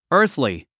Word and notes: earthly: ə’ː r θli; アースリィ

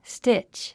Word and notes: stitch: stítʃ; スティチ

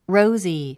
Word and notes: rosy: róuzi; ロウズィ

